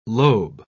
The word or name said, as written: lobe